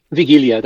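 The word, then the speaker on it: Wigilia: Vigilia, that's right.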